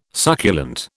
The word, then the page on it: succulent: British English